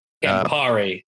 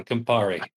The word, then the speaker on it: Campari: Campari. I pronounced that totally wrong. Campari. – Close enough.